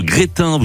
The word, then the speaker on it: Grestin: Alors ça s'écrit G-R-E-S-T-I-N, mais on prononce pas le S.